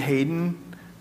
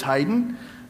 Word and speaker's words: Haydn: I was saying Hayden, but I was corrected right before the service that it's Haydn, I think.